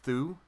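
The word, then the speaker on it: Thû: And Sauron is still not in it, but I think it's pronounced Thu. – Yeah. – T-H-U with an apostrophe is who Sauron takes a place of in the final version of it that you see in the Silmarillion.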